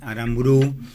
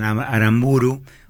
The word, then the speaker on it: Aramburú: Pierre Guérin, amigo de Federico Martín Aramburú, algunos dicen Aramburu, otro dice va el acento en la U final,